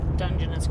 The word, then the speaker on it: Dungeness: I'm going to get a couple of crabs to put in there, but I will just go buy Dungeness crabs from the...